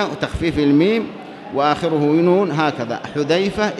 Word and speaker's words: حذيفة: حذيفة بهذا اللفظ، بهذا النطق، حذيفة بضم الحاء المهملة، فذال معجمة، فمثنى تحتية كما قال الشيخ، وهذا الشيخ الصنعاني، ففاء هو أبو عبد الله.